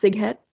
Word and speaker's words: Sighet: So it starts in 1941 and pronounced Sighet.